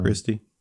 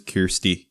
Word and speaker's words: Kirstie: Christy. I don't know. Let me look. It is Kirsty. Kirsty. It's K-I-R. Kirsty. – Okay. – I've been saying it wrong for most of my life.